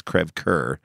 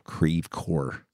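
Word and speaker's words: Creve Coeur: Dude, we lived in Crèvecœur, Missouri. If you studied French at all, that's Crèvecœur. – Yeah. – But St. Louisans called it Crèvecœur.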